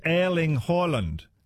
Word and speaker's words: Erling Haaland: Erling Haaland is pronounced in Norwegian like, it's Erling Haaland.